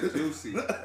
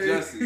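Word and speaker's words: Jussie: How you spell his name, I see why he kept calling the man Juicy. I'm like, this boy dumb, yo. He gonna read it like it's spelled. – Juicy Smoothie. – And not Jesse. Juicy.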